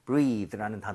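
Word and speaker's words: breathe: 발음도 breathe가 아니라 breath.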